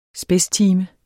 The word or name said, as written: spidstime